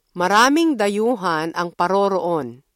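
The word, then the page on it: dayuhan: Filipino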